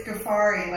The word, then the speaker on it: Ghaffari: It's Ghaffari, like safari.